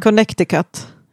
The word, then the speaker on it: Connecticut: Jag tror man uttalade Connecticut. – Connecticut, ja. Förlåt. Förlåt mig. Ja, Connecticut. Men det är okay.